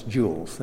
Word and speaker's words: joules: E is energy in the formula, in joules, J-O-U-L-E-S, joules, that's pronounced joules.